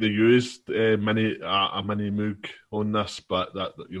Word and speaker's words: Moog: It's pronounced Moog.